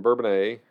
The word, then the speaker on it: Bourbonnais: Yeah, so I moved, lived in Bourbonnais. I'm pronouncing that right. – So yes, you are.